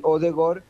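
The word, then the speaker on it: Ødegaard: Y Odegaard, cuando las dos A juntas en un apellido se dice O, no A.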